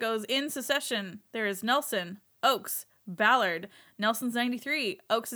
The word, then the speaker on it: Nelson: Number one, we kept pronouncing Russell M. Nelson's name as Nielsen. Apparently, so yeah, sorry. – I apologize. Nelson.